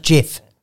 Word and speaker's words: GIF: Turns out it's pronounced GIF. – Right. – Right? It's G-I-F. – Yeah. – Not J-I-F.